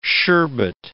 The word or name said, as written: sherbet